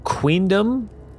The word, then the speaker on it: Queendum: And at the top of the map, you see that it is labeled and it's spelled odd, but you kind of sound it out and it's called Queendom and then UV and then Ocleria. And Queendom is spelled Q-U-E-E-N-D-U-M. Of is spelled UV and then Ocleria.